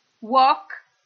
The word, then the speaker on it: walk: Walk, ecco, walk si pronuncia così, senza L, cioè quella L non esiste, così come per talk, che vuol dire parlare. Walk, camminare, talk, parlare, tutti e due hanno una sequenza LK pronunciando la quale si omette completamente il suono della L.